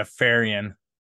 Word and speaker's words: Fahrion: Yeah, Fahrion.